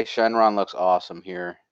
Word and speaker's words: Shenron: In Japanese, it's pronounced Shenron, which is what, like, it's called in the English dub as well.